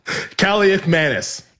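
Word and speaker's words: Kaliakmanis: It was 6-0 when Kalikamanis? How do you say it? – Kalikamanis. – Kalikamanis.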